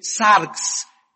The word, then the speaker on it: sarx: La palabra carne es una palabra griega que se escribe sarx, S de Samuel, A-R-X, sarx.